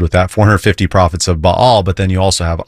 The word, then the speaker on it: Baal: And it's about Elijah and the prophets of Baal, or I often say Baal, because that's how you pronounce it in Hebrew.